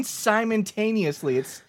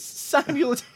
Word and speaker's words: simultaneously: You're saying simultaneously. It's simultaneously. You got me saying it wrong.